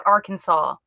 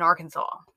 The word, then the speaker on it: Arkansas: And let me start off by saying it is illegal to pronounce it Arkansas in Arkansas. – So I was going to say, there. What? – So I'm not welcome there. I do apologize. I know it's Arkansas.